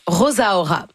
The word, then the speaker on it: Rosaura: Premier EP, assez court, à peine 18 minutes, 6 chansons de Talia Rosaura, où elle, elle prononce Rosaura. Donc, j'imagine que c'est comme ça qu'il faut le dire. Hein? – Ben, c'est comme Rosalia. – C'est ça. Ouais. Ben, R-O-S-A-U-R-A.